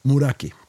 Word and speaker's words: Muraki: Till exempel, ska se om jag kan uttala det här, Murakai Atsuko, heter det så? Heter hon så? – Muraki. – Muraki. Muraki Atsuko.